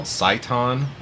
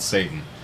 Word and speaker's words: seitan: seitan. Is that how they pronounce it? Or do they just call it Satan? – I think you're right the first time.